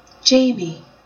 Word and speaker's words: Jaime: For example, here are the pronunciations of the name spelled J-A-I-M-E. And I didn't just pick that because I'm an Outlander fan. It's because it exists in so many languages. – Jamie.